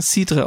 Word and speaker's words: Cidre: Weil im Französischen wird er sogar Cidre ausgesprochen. – Genau, der Cidre. – Und in England Cider.